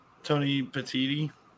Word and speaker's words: Tony Petitti: Tony Petitti.